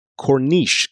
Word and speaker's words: Corniche: I believe it would be pronounced Corniche, because it's C-O-R-N-I-C-H-E, Corniche.